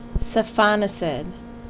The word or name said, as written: Cefonicid